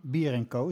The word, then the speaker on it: Bier Co: En eh, Rick is van Bier Co, zeg ik dat ook goed? Dat zeg je ook helemaal goed.